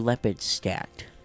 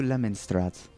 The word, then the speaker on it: Lepidstadt: And he welcomed you all to Lepidstadt. – Welcome to Lemonstrat. – Why do you just keep saying it wrong? – Welcome to Lemon Pepper.